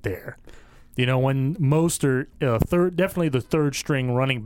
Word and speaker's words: Mostert: Is it Mostert or Mostert? I heard it both ways today. – Mostert. – Mostert with a long O.